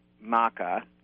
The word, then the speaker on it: maca: See, now you pronounce it the way I pronounced it over and over again. I had to unlearn maca, but I'm going to pronounce it the way you do because it oh, sounds oh, no, better I think to me. I think it's either.